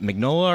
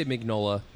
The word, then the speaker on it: Mignola: Based on the totally underrated film, I think, based on the comic book by Mike, is it Mignola or? – I always say Mignola. – Mignola? Yeah. – Could be mispronouncing it.